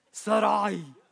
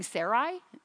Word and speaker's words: Sarai: And if one can roll the tongue, which I cannot do, it's supposed to be like Sarai, Sarai. Yeah, I'll be saying Sarai.